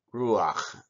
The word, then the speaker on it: ruach: The word for spirit in Hebrew is ruach. You pronounce it ruach with a ch.